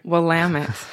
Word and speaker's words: Willamette: Willamette. Yes. In Oregon, they say rhymes with damn it.